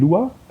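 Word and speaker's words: Luqa: und haben dann in 3 Tagen dort knappe 200 Sensoren verglät in Lua, also wird geschrieben Luca mit Q, aber ausgesprochen Lua, in der Nähe vom Flughafen, wo auch viele Flughafenreisende halt gerne mal parken.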